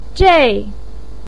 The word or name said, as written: zhei4